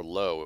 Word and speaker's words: Löwe: and Löwe, of lions, or low, it would be pronounced in English, like the theater chain, but Löwe means lions in German.